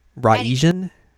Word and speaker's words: Raijin: Be like Raijin. – Raijin. – I don't know. That would be the more Japanese pronunciation of it.